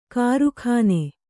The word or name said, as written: ಕಾರಕೂನ